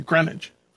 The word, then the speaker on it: Greenwich: Greenwich.